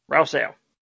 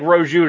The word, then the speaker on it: Rousseau: Rousseau. – All right. It looks like Rojo to me, and I keep wanting to say it, but I know that's not right.